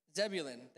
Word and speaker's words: Zebulun: Zebulun, thank you.